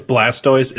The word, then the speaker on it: Blastoise: It's Blastoise. – Ah, okay. With a B, not a V. I thought you said Blastoise. – Sorry. It's Blastoise.